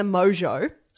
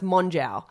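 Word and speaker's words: Mongeau: Jake Paul's new wife, / maybe not wife, is Tana Mojo. I'm always surprised that it's pronounced Mojo, but that's how she pronounces it. Looking at it, it looks like it should be pronounced Monjow.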